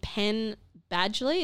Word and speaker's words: Penn Badgley: And Penn Badgley.